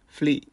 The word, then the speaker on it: flee: 残りの 3 つはそれほど難しくなくてですね、フリーマーケットのフリーと、あと動詞のフリー、逃げるのフリー、これらは全く発音が一緒で flee、 l ですね、 flee、これは比較的発音しやすいんじゃないかと思います。